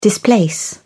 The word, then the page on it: displace: British English